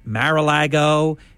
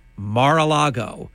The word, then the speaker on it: Mar-a-Lago: Oh, by the way, thank you for saying it correctly because I've been listening to the entire national media say Mar-a-Lago. It is like Bill Maher. Hate to say it that way, but it's Mar-a-Lago, and no one in the media says it correctly.